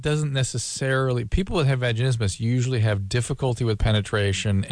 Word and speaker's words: vaginismus: I looked it up online and found something called, I don't know how you say it, vaginismus or vaginismus. – Yeah, vaginismus is a spasm of the floor of the pelvic musculature.